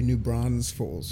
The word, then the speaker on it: New Braunfels: An S in the middle. There's no S in the middle. – New Braunfels. – I cannot pronounce that sound. I fuck it up every single time I pronounce it. It's just wrong. – What? – New Braunfels? – Say it one more time. – New Braunfels? – Yeah. New Braunfels. – You're putting an extra S.